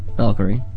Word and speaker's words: Valkyrie: Well, I have been playing a lot of Valkyrie? How do you say that word? It's a Valkyrie? – Valkyrie.